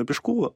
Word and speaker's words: Пешкова: Дед, известный мастеровой Василий Каширин, и его дочь выходит замуж за Максима Пешкова, изначально ударение ставилось на второй слог, за Максима Пешкова, вопреки воле своего сурового отца, потому что отец...